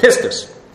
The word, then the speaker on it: pistis: Pistis. P-I-S-T-I-S. Really, I understand that according to Greek, if you translate this, it should be pronounced pistis, but unfortunately 30 years is hard to undo.